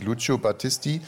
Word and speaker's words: Lucio Battisti: Es gibt es Lucio Battisti, Il mio canto libero. – Warte mal. Also ich finde, das ist relativ gut ausgesprochen. Also der heißt wirklich Lucio Battisti.